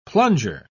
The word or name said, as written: plunger